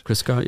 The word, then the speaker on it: Christgau: I read a thing just the other day, which was, you know, the critic Robert Christgau, I'm never sure how to pronounce that. – Christgau, yeah.